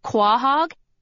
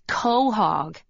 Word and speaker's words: quahog: Only they said it the way it's spelled, quahog, instead of the way it's supposed to be pronounced, which is quahog.